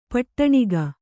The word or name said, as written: ಪಟ್ಟಣಿಗೆ